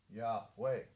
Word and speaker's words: Yahweh: And it was pronounced, we know from a variety of sources, as a two-syllable name, Yahweh. That's how it was pronounced.